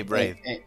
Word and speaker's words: Braid: O sea, hay juegos que están buenos, como el Braid, no sé cómo se dice nunca. – Sí, Braid.